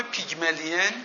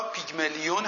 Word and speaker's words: پیگمالیون: پیگمالیون یا پیگمالیون هم ازش میشنوید، تلفظ مختلفی براش وجود داره.